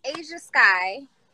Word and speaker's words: Ajhaskyyy: No, it's Ajhaskyyy.